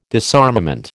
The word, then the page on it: disarmament: American English